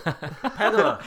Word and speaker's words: Pegula: Probably tomorrow they'll vote to make Terry, how do we say it, Pegula? – Pegula.